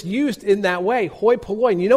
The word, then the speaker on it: hoi polloi: The Greek term is pronounced hoi polloi.